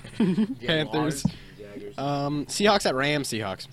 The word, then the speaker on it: Jaguars: Panthers at Jaggers. – Jaggers. – Let me change that over here too. – I'll go Jaggers. – How do you say it then? – Jaguars. – Jaguars. Let's go Jaguars.